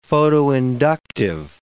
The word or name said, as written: photoinductive